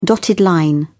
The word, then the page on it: dotted line: British English